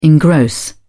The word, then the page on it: engross: British English